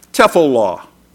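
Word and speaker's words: tephillah (תפילה): The Hebrew word most often translated prayer is pronounced tephillah, and it means intercession or supplication.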